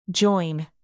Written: join

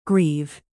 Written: grieve

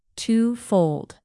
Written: twofold

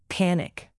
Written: panic

